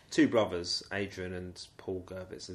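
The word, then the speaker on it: Gurvitz: But he's forced out of Nigeria, comes to Britain, and joins, or helps to form, the Baker Gerwitz Army in 1974. – You're pronouncing it with a W, yeah? – Is it a V? – Well, it's a V, isn't it?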